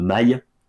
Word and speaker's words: mail: Et je tiens d'ailleurs à publier un erratum officiel sur ma dernière chronique où j'ai proposé jeu de mail à l'anglaise, parce que mail, est maille, je le prononce mail, alors qu'en fait on dit jeu de maille, comme la moutarde, il n'y a que maille qui maille, car le mot est dérivé de maillet.